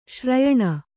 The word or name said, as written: ಶ್ರಯಣ